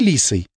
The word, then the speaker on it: Лисой: «Зови Лисой». Она сделала ударение на первом слоге.